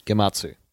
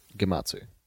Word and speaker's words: Gematsu: G-E-M-A-T-S-U. – Gematsu. – Gematsu? – Gematsu.